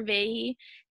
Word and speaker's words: Vahey: So my Instagram is Taylor Vahey, T A Y L O R V A H E Y.